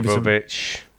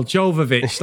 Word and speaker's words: Jovovich: But Jovovich and is it Jovovich? What the hell? I keep saying their fucking name wrong. – Jovovich. – Here as well. Jovovich, that's it.